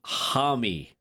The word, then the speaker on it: Homme: Somebody texted in, you pronounce his name Josh Homme. Okay, we'll go with that.